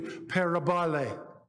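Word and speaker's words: parabole (παραβολή): But this is how you pronounce it in the Greek. And it's literally parabole. That's how you would say it.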